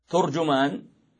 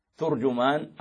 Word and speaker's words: ترجمان: ترجمان فيها ست لغات، تنطق بضم التاء والجيم وبفتحهما وبفتح التاء وضم الجيم، أو العكس بضم التاء وفتح الجيم. – هذه أربعة. – نعم، فلهذا لا يخطئ قارئ فيها. ترجمان أو ترجمان أو ترجمان أو ترجمان، كلاهما صحيح بهذه الأوجه.